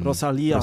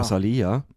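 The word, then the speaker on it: Rosalía: Sie, ähm, Rosalia. Rosalia. Bitte Betonung.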